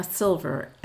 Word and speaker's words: Silver: Donna Silver, S-I-L-V-E-R.